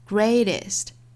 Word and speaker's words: greatest: 那首先我们来看 greatest，中间的这个字母 t 的发音在两个元音之间呢，美音倾向于把它发成一个 flap t， greatest The greatest good you can do for another you can 两个词做弱读 you can do for another, you can do for another.